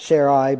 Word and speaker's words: Sarai: You know, you should pronounce it Sarai, but I'm not going to.